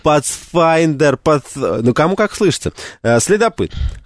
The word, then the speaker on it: Pathfinder: А Pathfinder? – Ну, потому что пишется через TH, поэтому это звук... – Это не Z, нет, это... – Да, это там потом глухой звук. – Pathfinder.